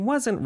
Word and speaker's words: wasn't: 네, 요거 이제 발음을 원어민처럼 해보려면 wasn't 이 부분이 가장 중요할 것 같아요. W를 시작하는 거는 앞에 의가 숨어있다라고 생각하면서 입모양만 그렇게 만들어주면 되고요. T는 있는 듯 없는 듯 느낌만 주면 됩니다.